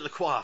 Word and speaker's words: LaCroix: Oh, and maybe you may have to count to pronounce the F, LaCroix, because it's like a coif, like your hair. – Oh, is it LaCroix? – Yeah.